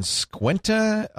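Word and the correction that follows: Skwentna: Alaska? Am I saying that right? Squinta? – Squintna. – Squintna.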